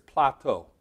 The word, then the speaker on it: Plato: And while I was over in Greece this year, it's Plato. I kept saying it wrong.